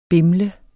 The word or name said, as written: BIMLER